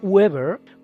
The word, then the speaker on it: Weber: la unidad de flujo magnético, el Weber, por la pronunciación inglesa que utilizamos también en español, se llama así en su honor.